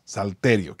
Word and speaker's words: salterio: Y no te dice salterio, que no dice salterio, no hay acento en la I. Okay, no es con salterio, no vas a andar saltando. Es salterio, que es un tipo de guitarra.